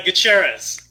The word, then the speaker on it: Gutierrez: It's Gutierrez.